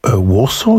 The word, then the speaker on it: Walsall: Warsaw? You mean in the Black Country? Because there's a place in England near Birmingham called Warsaw. Mm-hmm. It's spelt Walsall.